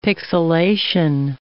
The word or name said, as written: pixilation